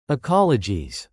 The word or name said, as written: ecologies